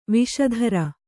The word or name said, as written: ವೇಷಧಾರಿ